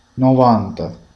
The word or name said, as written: 90